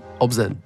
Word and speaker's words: ObZen: Obzen, yeah.